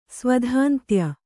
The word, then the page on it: ಸ್ವಧಾಂತ್ಯ: Kannada